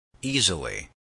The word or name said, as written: easily